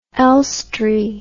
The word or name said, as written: elstree